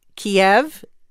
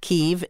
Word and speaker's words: Kyiv: Kiev is the Russian pronunciation of that city. Kyiv is the Ukrainian pronunciation, and the Ukrainians are very proud of their own language and want that city pronounced that way, and that's why diplomats do that.